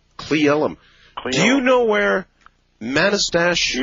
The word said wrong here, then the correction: Manastash: Yeah, it's pronounced Manastash. – Figures. Manastash, all right.